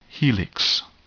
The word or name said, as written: helix